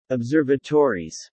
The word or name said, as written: Observatories